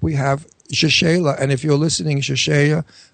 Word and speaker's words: Rachele: Rachele. – I wish her name was Mary, but anyway, it's Rachele.